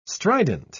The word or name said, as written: strident